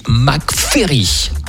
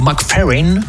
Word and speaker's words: McFerrin: Dans un instant, il y a un collector de Bobby McFerry. Attention, ou McFerrin, ça dépend si vous voulez le prononcer à l'anglaise ou à l'américaine.